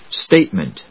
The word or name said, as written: statement